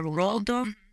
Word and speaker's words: Roald Dahl: Well, I heard that the Roald, is that how you pronounce it? Roald Dahl? – Yeah, Roald Dahl.